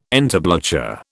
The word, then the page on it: entablature: British English